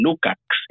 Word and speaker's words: Lukacs: En primer lugar, en mis redes sociales, a través de Miklos Lukacs, que es bastante difícil de... no, no se escribe como, se, como suena, es M-I-K-L-O-S, Miklos, es mi nombre, y Lukacs, L-U-K-A-C-S es mi apellido.